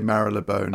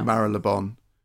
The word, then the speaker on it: Marylebone: And everyone seems to pronounce Marylebone slightly differently. Some people say Marylebone. – Oh, yeah. – Marylebone. You've got to take your cue from the tube station announcements, I think.